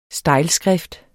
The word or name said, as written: stejlskrift